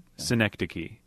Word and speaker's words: Synecdoche: I know it rhymes with Schenectady. It would be Schenectady? – Schenectady.